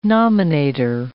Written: nominator

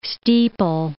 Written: steeple

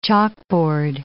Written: chalkboard

